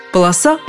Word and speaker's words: полоса: Пусть ударение везде будет на окончании. Полоса, полосы, полосе полосу, полосой, о полосе.